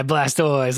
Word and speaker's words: Blastoise: And on the front is, I don't know how to say this dude's name, the turtle that is blowing stuff up, Blastoise? – Yeah, Blastoise, let's go.